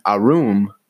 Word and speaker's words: Aurum: Now, if you want the wheels painted in satin arum, I don't know how the fuck you say that. A-U-R-U-M.